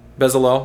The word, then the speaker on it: Bezalel: Is that the right pronunciation? Bezalel, more or less.